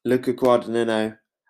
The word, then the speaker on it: Guadagnino: Luca Guadagnino.